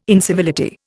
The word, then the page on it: incivility: Indian